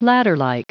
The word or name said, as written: ladderlike